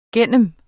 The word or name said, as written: gennem